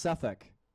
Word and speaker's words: Suffolk: It's pronounced Suffolk, as in Suffolk, like suffering fuck.